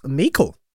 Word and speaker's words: Meikle: Oh, Ross Meikle, rhymes with treacle.